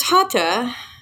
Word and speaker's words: hatha: There's hatha. It's spelled H-A-T-H-A, but the H is silent, like so many words in Indian words. And the general public is more familiar with hatha.